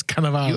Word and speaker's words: Cannavale: Okay, so along with Hawke, the film stars Andrew Scott, Margaret Qualley, Bobby Cannavale. Did I say that right? – Yes, Cannavale.